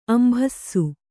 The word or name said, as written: ಅಂಭಸ